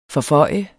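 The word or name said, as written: FORFØJ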